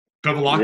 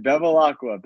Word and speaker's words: Bevilacqua: Bevilacqua? Yeah, you got it, man. Bevilacqua.